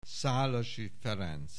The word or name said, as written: Szalasi Ferenc